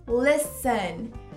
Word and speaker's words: listen: Então a palavra escutar, ouvir, listen. Listen, você ignora o T lá.